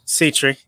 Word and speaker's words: Setree: Setree.